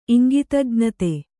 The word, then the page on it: ಇಂಗಿತಜ್ಞತೆ: Kannada